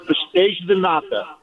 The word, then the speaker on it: pastéis de nata: Now, being Portuguese descent, that dessert you guys are talking about, which is pronounced pastéis de nata, right? – It is like the Portuguese cannoli, right?